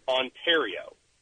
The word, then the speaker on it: Ontario: I constantly want to pronounce the word Ontario. So, that one was the correct one. – See, right, Ontario, no right.